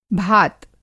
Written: भात

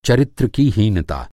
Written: हीनता

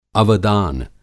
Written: अवदान

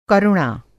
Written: करुणा

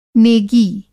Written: नेगी

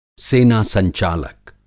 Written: सेनासंचालक

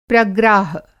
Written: प्रग्राह

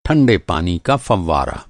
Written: फ़ौवारा

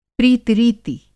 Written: प्रीति-रीति

